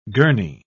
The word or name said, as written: gurney